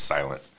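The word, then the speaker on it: SLNT: And it's S-L-N-T, it's pronounced Silent.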